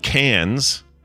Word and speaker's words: Cairns: This is C-A-I-R-N-S, right? – Correct, yes. – That if that is spelled Cairns, then that's effed.